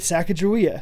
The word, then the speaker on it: Sacagawea: And I'm like, okay, that's how you pronounce it, right? Sacagawea.